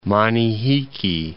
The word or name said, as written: Manihiki Islands